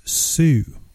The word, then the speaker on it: TSU: And it's actually pronounced Sue, like SUE. The T is actually silent.